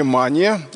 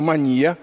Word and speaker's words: мания: Вот есть настоящая мания, или, как говорят психиатры, мания. У психиатров свои ударения.